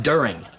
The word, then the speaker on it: during: De-urine. – What? – You said de-urine. It's pronounced during.